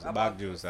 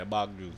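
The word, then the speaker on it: bawg juice: No, because you didn't say it right. – It's bog. – Yeah. – Bog. B-A-W-G? – Yeah, bog juice.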